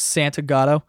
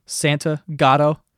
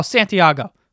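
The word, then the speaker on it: Santagato: You know, it's pronounced how you fucking read it. Santa Gatto. People see S-A-N-T and they see a G in there and they go, oh, Santiago, and they're like, oh, he's Spanish.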